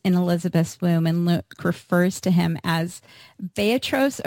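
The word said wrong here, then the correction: Brephos (βρέφος): or can you pronounce it for me? Brephos, yes. – Brephos.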